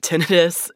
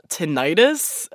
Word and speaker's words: tinnitus: But one of the most common symptoms to pay attention to is tinnitus yeah, it's pronounced tinnitus, not tinnitus. Common mistake.